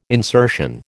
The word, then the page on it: insertion: American English